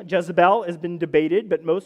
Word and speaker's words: Jezebel: In its original Phoenician pronunciation, Jezebel.